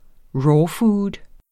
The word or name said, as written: rawfood